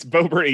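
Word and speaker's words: Boebert: And then I start getting anxious about, like, oh fuck, did I vote for Babert? Is that how you say her name? – No. – Babert? – Bobert, I think. – It's Bobert.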